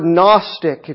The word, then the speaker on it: Gnostic: The word Gnostic, if you're writing it down, is spelled with a silent G at the beginning. Kind of like gnocchi, the little potato dumplings. Those are yummy. Comes from the Greek word gnosis, G-N-O-S-I-S, which means knowledge.